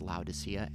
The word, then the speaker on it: Laodicea: But he also said to the church of Laodicea, and I believe it's pronounced Laodicea, L-A-O-D-I-C-E-A, Laodicea.